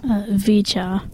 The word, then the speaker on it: VCHA: VCHA.